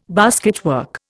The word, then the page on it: basketwork: Indian